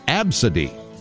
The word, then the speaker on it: Abcde: No, the name A, B, C, D, E is pronounced Absidy. Okay? You want to name your dog Dog? D-O-G?